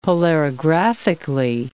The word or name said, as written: polarographically